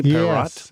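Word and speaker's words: Parrott: Nikki Perrott, I'm saying that correctly, Perrott? – Yes, she's from Sydney, Australia.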